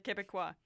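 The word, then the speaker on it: Québécois: You could just say the BQ for ease of pronunciation, but it's like Quebecois.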